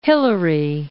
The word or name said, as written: Hillary